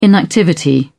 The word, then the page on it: inactivity: British English